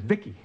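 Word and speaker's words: V.I.C.I.: V-I-C-I, I, I pronounce it Vicky everybody, Angela Bowen here, the host of She's a Small Wonder, a Small Wonder podcast.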